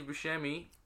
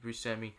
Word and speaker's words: Buscemi: Well, he said, there's an interview he went with on Colbert where he's like, Steve Buscemi... Semi, he says Semi. I pronounce it Mr. Steve Buscemi, and my father pronounced it Buscemi, but extended family would pronounce it Buscemi. And in Sicily, where the name comes from, they say a Buscemi.